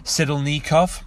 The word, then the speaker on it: Sidelnikov: I always say that wrong. Sidelkinov. Sidelnikov. Kirill Sidelnikov.